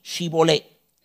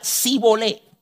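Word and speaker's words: Shibboleth: Si respondía no, entonces les decían, ahora pues, di Shibolé. Y él decía Sibolé, porque no podía pronunciarlo correctamente.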